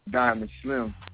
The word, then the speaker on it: Dymon Slim: And my name, Diamond Slim, I spell it different.